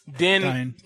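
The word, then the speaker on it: Dyn: They grabbed all these devices, and they used those devices to launch a massive DDoS attack against DIN. So I think I'm saying that right. – Dyne. – There you go.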